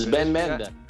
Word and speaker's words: Sven Bender: Sven Bender.